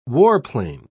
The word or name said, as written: warplane